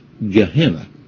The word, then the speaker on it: Gehenna: Now, I don't know how familiar you are with the valley of the son of Hinnom, but in later Greek transliteration, this term, the valley of the sons of Hinnom, is going to be pronounced Gehenna.